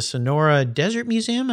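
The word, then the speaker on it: Sonoran Desert Museum: Another one's the Sonora Desert Museum. Am I saying that right? – Yes. Beautiful.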